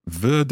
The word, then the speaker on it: wird: Wird. It's a kind of T sound at the end of that. Wird es schneien. – Yeah, but it's spelled with a D.